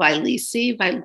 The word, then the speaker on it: Vyleesi: yeah, So speaking of that, the drug is called Vyleesi, V Y L E E S I.